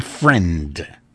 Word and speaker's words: Freund: There are a lot of people who spell their name my way, F-R-E-U-N-D, who say friend. – Well, it's like our friend Leslie, K-L-A-I-N-B-E-R-G, and the family fight is whether it's Kleinberg or Kleinberg.